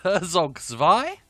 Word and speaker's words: Herzog Zwei: Herzog... I've said it wrong already. Herzog Zwei? Yes, we've got Capcom versus SNK2.